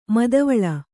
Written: ಮಾದವಾಳ

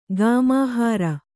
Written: ಕಾಮಹರ

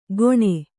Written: ಘೋಣೆ

